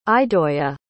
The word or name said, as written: idoya